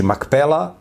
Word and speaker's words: Makpela: Znači, Makpela, jer P i F u hebrejskom isto slovo, zavisi od konteksta, se nekad izgovara P, nekad F, to slovo P ili Fe. Znači, Makpela doslovno znači dupla pećina.